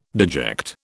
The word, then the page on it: deject: British English